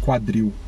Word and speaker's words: quadril: Yeah, just like, for instance, it comes from the same radical as quadriceps. Quadriceps. Yeah. So quadril. It's so Q U A D R I L. I L. Quadril.